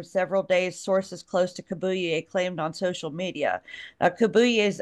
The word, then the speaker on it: Kabuye: His name's Stephen Kabuye. And I got a check on like how to pronounce that. So that should be right. – K-A-B-U-Y-E. So yeah. I think it's pretty Kabuye. Darn close.